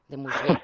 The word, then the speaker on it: Desmurget: Bueno, pues recomendamos de verdad La fábrica de cretinos digitales de Michel Desmurget. ¿Se pronuncia así? – De Mourget, venga, perfecto.